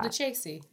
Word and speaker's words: Lucchese: Lucchese. – Lucchese? Lucchese. – Oh, it's CC. I knew that. – Double C is a hard C. – Double C is a K.